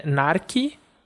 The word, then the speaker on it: narki: É narki, que tem uma fechada, nar, e uma aberta, ki, vai ser que com a força na primeira sílaba, e significa gato.